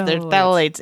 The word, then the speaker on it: phthalates: It's the phthalates in PVC, and it has it starts with a PH. It's phthalates, and yeah. – And she goes, well, the pH is silent.